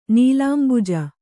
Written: ನೀಲಾಂಬುಜ

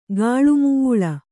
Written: ಗಾಳುಮೇಳು

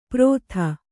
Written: ಪ್ರೋಥ

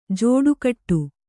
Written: ಜೋಡುಗೂಡು

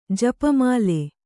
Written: ಜಪಮಾಲೆ

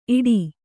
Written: ಈಟಿ

